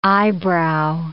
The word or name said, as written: eyebrow